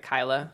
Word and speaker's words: Kaela: I thought it was K-A-E-L-A. – Yeah. In English, they just pronounce it Kyla. – Oh, okay. – So I just pronounce it Kyla as...